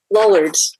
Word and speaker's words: Lollards: I've read that one of your areas of expertise is about the Lollards. Am I pronouncing that correctly? – Lollards.